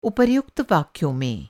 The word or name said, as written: उपर्युक्त